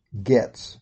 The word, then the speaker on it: Goetz: That's spelled G-O-E-T-Z. And this name is pronounced Getz.